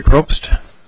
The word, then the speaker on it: Probst: Hi, my name is Marty Probst. – Can you spell that, please? – P-R-O-B-S-T.